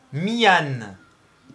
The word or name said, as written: myan